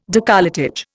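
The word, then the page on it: décolletage: Indian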